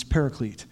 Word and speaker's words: paraclete: The original word is parakletos, Unfortunately, the way we pronounce it when we anglicize it or we, when we use it in an English form, it's paraclete, which sounds too, mu- too much like parakeet to um, use of the Holy Spirit, so we won't do that.